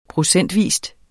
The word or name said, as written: procentvis